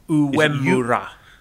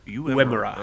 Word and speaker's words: Uemura: Urama, I can't pronounce his name. How do you pronounce it? – Uemura. – Uemura. Oh, all right.